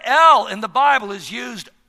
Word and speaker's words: El: But the particular word that Isaiah uses here in chapter 9, verse 6, is the Hebrew word El. You spell it E-L, El.